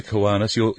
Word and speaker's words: Kiwanis: That's K-I-W-A-N-I-S.